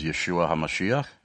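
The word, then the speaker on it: Yeshua HaMashiach (ישוע המשיח): Ron Elkin is a Jewish believer in Jesus Christ, also known in Hebrew as Yeshua HaMashiach.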